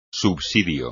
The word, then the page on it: subsidio: espagnol